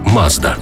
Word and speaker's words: Mazda: Назва також походить від імені засновника компанії Мацуда, яке японською вимовляється як Mazda.